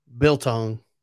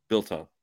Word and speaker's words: biltong: Biltong. – Biltong.